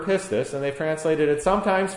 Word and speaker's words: pistis: It's a Greek word, pistis. Spell that P-I-S-T-I-S.